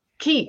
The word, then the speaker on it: Quai: Key. Like the key energies. Yeah. But it's spelled QUAI, so I screwed that up. – Oh no, listen, it's French, so it's like keys are like things that keep a shore from washing away, like on a riverfront or like beach.